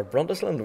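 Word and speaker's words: Burntisland: And then I have Brunt Island, or Bruntisland, whatever you want to pronounce that. – Bruntisland. – Bruntisland, aye.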